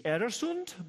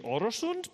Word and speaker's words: Öresund: It's the Öresund Bridge, if you're Swedish, and the Öresund Bridge, if you're Danish, so slightly different pronunciation.